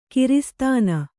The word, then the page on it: ಕಿರಿಸ್ತಾನ: kiristāna